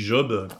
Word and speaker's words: job: En fait, pour prononcer le Job qui nous intéresse, il y faut un J glissant sur le J, et dire job, comme on dit jackpot ou jazz, ou alors le prénom Jamel.